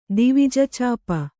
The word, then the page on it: ದಿವಿಜಚಾಪ: divija cāpa